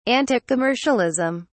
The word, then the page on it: anticommercialism: British English